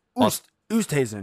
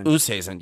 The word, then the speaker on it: Oosthuizen: Louis Oosthuizen. – Oosthuizen.